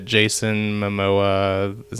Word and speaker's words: Jason Momoa: I really liked how it was going, and I've seen clips, obviously, like Jason Momoa. Is that how you pronounce his name? – That's it. Yeah.